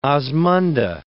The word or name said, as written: osmunda